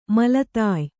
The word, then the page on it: ಮೇಲ್ದಾಯ: Kannada